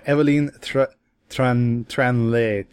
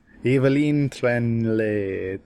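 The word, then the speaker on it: Évelyne Tranlé: Pierre Christin and Jean-Claude Mezières, with colors by Evelyne Tranley. I was doing so well until the end. Evelyne Tranley. Yeah. Pierre Christin and Jean-Claude Mezières. – Awesome. Good job.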